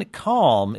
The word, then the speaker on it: Kalm: Justin Kalm is joining us. Justin, am I pronouncing your last name right? – Yes, perfectly. – K-A-L-M, Justin Kalm from San Diego.